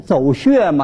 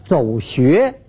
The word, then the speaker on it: 走穴: 演艺圈里头啊，有一种专业用语，像什么走穴呀、啊、哎，那不说不念走穴吗，这读音读错了啊，应当是走穴。